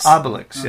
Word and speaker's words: Obelix: Obelix? – Obelix, yeah.